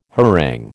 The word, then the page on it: harangue: American English